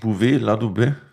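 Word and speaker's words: Bouvet-Ladubay: Und wie spricht man, habe ich es richtig ausgesprochen, Bouvet, La Dubé? – Das ist perfekt. Hast du perfekt gesprochen.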